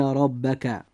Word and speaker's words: ربك: نلاحظ أن حرف الراء في كلمة ربك جاء مفتوحا، لذلك يجب أن يفخم فينطق هكذا: إن ربك.